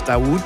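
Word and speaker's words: Dauwd: I don't quite know how to pronounce that. D-A-U-W-D, I think it's Dawood.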